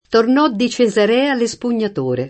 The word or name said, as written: Cesarea